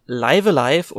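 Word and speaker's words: Live A Live: Und da würde ich auch gerne einfach nochmal zur Klarstellung, ähm, dass Live Alive tatsächlich der korrekte Titel ist, ähm, da auf die Katakana-Schreibweise hinweisen, denn an der Schreibweise, also sprich einer der Silbenschriften, die es im Japanischen gibt, kann man eigentlich immer ziemlich gut erkennen, wie ein Wort ausgesprochen wird und das wird halt Raibu Araibu geschrieben, also live alive.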